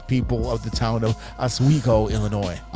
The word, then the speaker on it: Oswego: Yes, and it's called it's pronounced Oswego. So, you know, that was a very very mediocre way to say that town.